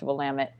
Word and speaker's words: Willamette: Willamette. – Yes. You might be familiar with Willamette Valley Power Yoga. It's one and the same, but it's pronounced Willamette.